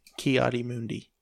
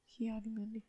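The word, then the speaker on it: Ki-Adi-Mundi: How did you say that guy's name? – Ki Adi Mundi. – Ki Adi Mundi.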